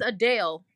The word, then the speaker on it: Adele: Sorry, it's not Adele. It's Adele. I'm sorry. It's Adele. Because we've been pronouncing her name wrong. It's Adele. She can't. She's like, my name is actually pronounced Adele.